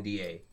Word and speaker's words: Wauconda: Did you know that there is it's spelled differently, Wakanda, Illinois. – Really? – Said the same way, Wauconda.